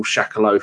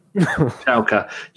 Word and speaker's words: Schalke: I call Shakalo for, but that's not how you say it, is it? Schalke.